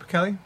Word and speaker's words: Pichelli: How you say it, Pichelli? – I believe so.